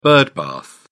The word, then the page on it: birdbath: British English